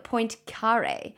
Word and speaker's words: Poincaré: So they reckon that Henry Poincaré, don't know if I'm pronouncing that correctly or incorrectly. – Good. – Poincaré, if you're still alive, then I'm sorry.